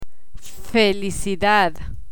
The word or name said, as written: felicidad